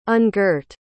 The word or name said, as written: ungirt